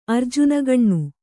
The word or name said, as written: ಅರ್ಜುನಗಣ್ಣು